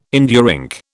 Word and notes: India ink: British English

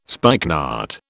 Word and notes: spikenard: British English

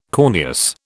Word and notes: corneous: British English

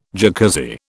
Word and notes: Jacuzzi: British English

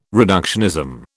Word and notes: reductionism: British English

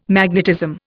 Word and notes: magnetism: Indian

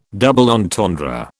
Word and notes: double entendre: British English

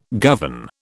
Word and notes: govern: British English

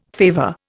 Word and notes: favour: Indian

